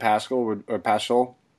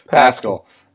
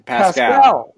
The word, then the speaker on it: Pascal: I think Bowman and Pascal. Excuse me if I'm pronouncing that wrong. – Pascal. – Those would be my two primary options. Just Pascal. Yeah, Eric Pascal.